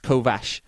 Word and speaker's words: Kovacs: So Takeshi is his first name, Japanese, but you don't really know him as that, you know him as Kovacs, but it's not Kovacs, it's pronounced Kovash or Kovash, something like that.